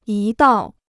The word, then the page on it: 一道: yī dào